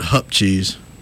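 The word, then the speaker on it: hoop cheese: I like how you called it hook cheese. – Yeah, it's spelled H-O-O-P, but from the time I remember, I always remember it being hook cheese.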